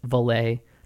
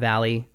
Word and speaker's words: Valet: It's Valet, not Valley.